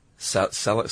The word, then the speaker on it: salicylic: And there are also some over-the-counter home remedies that have salicylic. Is that how you pronounce that? – Yeah, that's how I salicylic acid as the active ingredient.